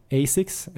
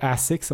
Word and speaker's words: Asics: Nu är det så att Löplabbet här i mars kommer göra en ganska rolig grej tillsammans med Asics, eller Asics om man föredrar den, det uttalet. Eh, eller Azix om man vill eh, prata lite mer franskt uttal.